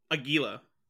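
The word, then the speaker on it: Águila: Aguila. – How do you pronounce it? – Aguila. – Aguila. Aguila. Okay.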